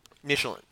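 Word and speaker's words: Midtjylland: Or Midgetland, as he was called when Man United played. – Yes. – When English people couldn't figure out how to pronounce the Danish club's name.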